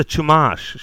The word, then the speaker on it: Chumash: It's with a CH, but I think it's pronounced Shoemash. I feel like I've heard the word Shoemash before. – Oh, it's a tribe? – Yeah. – It's the Chumash, Shibble.